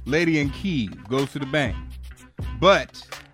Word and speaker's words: Kyiv: That's how you say it, in Kyiv.